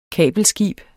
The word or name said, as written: kabelskib